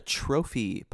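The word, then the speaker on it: trofie: I had a trophy pasta. Have you heard of this? I think that's how you say it. It's T-R-O-F-I-E.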